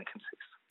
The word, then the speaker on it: chemsex: chemsex. – Alors, expliquez-nous ce qu'est d'abord le chemsex, parce que ça s'écrit C-H-E-M-sex, et chemsex, chemsex, comment prononce-t-on ? – Chemsex, ouais.